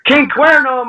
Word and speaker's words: King Cuerno: And now your first dark match was King Cuero. What was it like wrestling him, if I said his name right? – King Cuerno, man. King Cuerno.